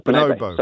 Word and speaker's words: bonobo: How do you pronounce it? – The ape. Bonobo. – Bonobo, sorry. – He said it better.